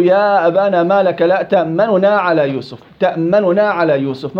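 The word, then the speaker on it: تأمنّا: تأملنا قلنا فيها وجهان للقراء السبعة، أو في رواية حفص الاختلاس، وعبر عنه الروم مع الإخفاء، نطق النون أو ضمة النون الأولى بسرعة، تأمننا، ما بريحها، ما لك لا تأمننا، سلام، تأمننا، ريحت الضمة أنا.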